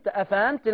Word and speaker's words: أفأنت: لا بص، أفأنت. لا، حققي الهمزات.